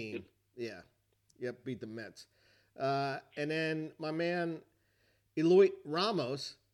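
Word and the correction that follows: Heliot: That's not how you pronounce his first name. – No. I was watching the Giants game, and they had his family there. It was his first game in San Francisco. Not his first major league game, but first game in San Francisco. It's pronounced Elliot Ramos. – I wouldn't have guessed that. – A silent H. – The H is silent.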